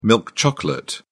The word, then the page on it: milk chocolate: British English